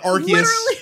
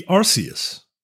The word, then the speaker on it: Arceus: Are there any other words that have a hard C followed by an E? Right. Cause it's like, cemetery, century. It would be Arceus, right? – I think so.